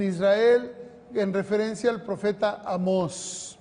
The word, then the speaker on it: Amós: No, Amós, tiene un acento en la O.